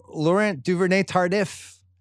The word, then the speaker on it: Laurent Duvernay-Tardif: Right guard, Laurent Duvernay-Tardif. Is that how you say it? – Yeah, you nailed it.